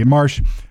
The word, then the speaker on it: Marche: More German armored units have crossed the river near or, or the river Ourthe near Ortheuville and have taken uh, Marsh. How do you say that? M A R C H E Marsh, and uh, reached Saint Hubert by passing through Humbre Siebert Tille.